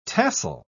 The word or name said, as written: tassel